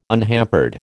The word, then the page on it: unhampered: American English